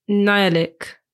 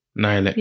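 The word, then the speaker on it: Nhialic: Okay, so it's spelled N-H-I-A-L-I-C-K. I would say Nialik. – Nialik.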